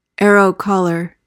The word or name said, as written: arrow-collar